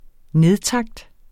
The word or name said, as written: nedtakt